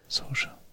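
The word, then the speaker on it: Saoirse: Sosha Ronan. S Sosha. Mhm. Sosha.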